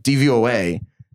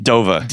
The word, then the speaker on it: DVOA: An initialism is pronounced as individual letters, like DVOA, MVP. – DOVA. – DNA. – Instead of DOVA.